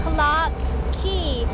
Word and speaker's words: Clark Quay: ก็จะมีสถานที่นะคะที่มีชื่อว่า Clark Quay สะกดหน้าตาแปลกๆนิดนึงนะ QUAY แต่คำนี้อ่านว่า Key เหมือนคีย์กุญแจแล้วค